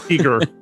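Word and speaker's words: Giger: Geiger's fantasy nightmare? I don't know. – Eager, Patrick.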